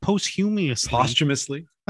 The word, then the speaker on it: posthumously: How do you say it? Posthumously. Yeah, that's right.